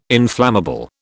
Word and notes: inflammable: British English

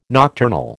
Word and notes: nocturnal: American English

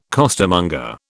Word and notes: costermonger: British English

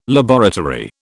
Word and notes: laboratory: British English

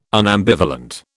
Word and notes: unambivalent: British English

